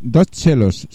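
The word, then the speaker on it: 2Cellos: Son Dos Chelos, se llaman. – ¿Sí? – Se escribe Dos Cellos.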